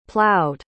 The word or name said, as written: plowed